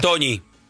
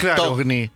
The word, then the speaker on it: Togni: Toñi. – No. Y él dice, no, mi apellido se pronuncia Togni.